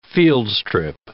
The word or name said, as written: fieldstrip